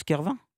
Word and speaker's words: Carvin: Le Pôle emploi de Carvin, on prononce Carvin? – Carvin, oui, dans le Pas-de-Calais.